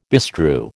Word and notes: bestrew: American English

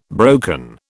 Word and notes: broken: British English

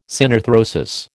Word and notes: synarthrosis: American English

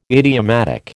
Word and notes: idiomatic: American English

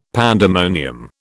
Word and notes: pandemonium: British English

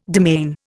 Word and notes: demesne: Indian

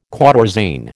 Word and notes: quatorzain: American English

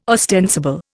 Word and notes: ostensible: Indian

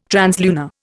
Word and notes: translunar: Indian

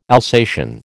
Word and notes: Alsatian: American English

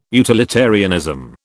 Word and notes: utilitarianism: British English

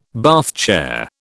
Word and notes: Bath chair: British English

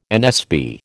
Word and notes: NSB: American English

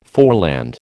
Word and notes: foreland: American English

